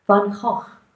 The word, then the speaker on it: Van Gogh: I love the painting and the interpretation of Vincent van Gogh, or to pronounce it with some of my Dutch heritage, Van Gogh.